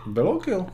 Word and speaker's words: Bellocchio: Protože Bellocchio.